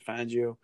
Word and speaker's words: Fangio: Fangio is that how you pronounce it? Fangio? – Yeah, he's taken responsibility for the time management blunder.